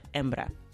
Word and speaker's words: hembra: And that's actually spelled with an H. And as you know, in Spanish, the H is not pronounced in any of the words. So it's H-E-M-B-R-A, hembra.